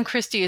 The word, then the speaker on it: Kristi: So For those listening, the name Kristi is spelled many different ways, as you might be aware. Mine is K-R-I-S-T-I.